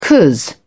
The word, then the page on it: cos: British English